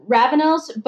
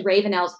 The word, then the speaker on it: Ravenels: And oh my gosh, I've loved, is it the Ravenels? How do you pronounce it? – Actually I pronounce it, Ravenels, but yes. The audio reader, Mary Jane Wells, is so extraordinary. And she has this beautiful British accent. She says Ravenels, so she must be right.